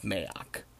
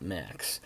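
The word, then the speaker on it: Maax: And in that kingdom, there is a scheming high priest who goes by the name of Mayok. I'm going to have to do a sidebar here. Mayok is spelled M-A-A-X. I have heard many, many, many people mispronounce it and call it Max.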